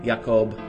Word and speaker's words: Jakob: It's J-A-K-O-B, simple enough. The J in Danish is pronounced as a Y, so Jakob is what you're going to get in the first name.